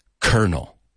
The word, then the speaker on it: colonel: En inglés se escribe colon, como colón, colonel. Sin embargo, se pronuncia colonel.